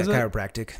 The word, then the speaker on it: chiropractic: So, what made you want to get into chiropractic? Is that how you say it? – Chiropractic? – Yeah, chiropractic. – Chiropractic. It's such a weird word when you say it. – It ends in tick.